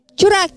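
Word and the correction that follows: Curaçao: Blue Curaco. – Curaco.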